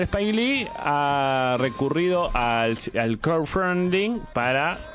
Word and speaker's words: crowdfunding: ¿Cómo se dice? – Crowdfunding. – Hoy empecé a estudiar inglés, así que si me equivoco en la pronunciación, quiero que me corrijas, así cuando vaya a mi profesora, ella se sorprende. – Es crowdfunding. – Crowdfunding. ¿Bien ahí? – Un poquito mejor, sí, muy bien.